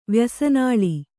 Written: ವ್ಯಸನಾಳಿ

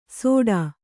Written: ಸೋಟ

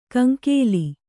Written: ಕಂಗೋಲು

